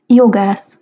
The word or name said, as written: jogasz